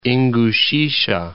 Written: Ingushetia

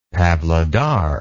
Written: pavlodar

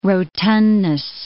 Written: rotundness

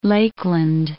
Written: Lakeland

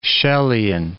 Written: Chellean

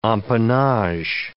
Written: empennage

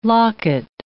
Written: locket

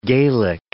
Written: Gaelic

